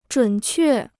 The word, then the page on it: 准确: zhǔn què